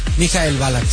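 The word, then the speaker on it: Michael Ballack: ¿Cómo se dice bien? ¿Mikhail Balak? – Mikhail Balak, sí.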